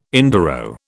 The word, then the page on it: enduro: British English